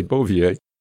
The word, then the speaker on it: Bouvier: I forget his first name, but his last name was, is it Bouvier? Is that the way you pronounce it? – Yep. – Okay, Bouvier.